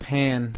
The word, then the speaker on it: pain: I think it's pronounced pan. – Pan? – Okay. In French. – But spelled pain. – Same spelling. – P-A-I-N.